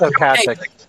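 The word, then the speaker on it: Ocasek: Rico Kasich or Okasic from the Cars. – It's Okasic. – Okasic. – It's really Okasic. that's how he pronounces it.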